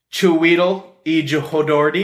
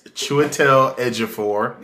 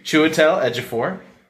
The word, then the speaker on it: Chiwetel Ejiofor: Doctor Strange, director Scott Derrickson, Benedict Cumberbatch, Chiwetel Ejiofor. – Hold up, that's Chiwetel Ejiofor. – Thank you, Chiwetel Ejiofor.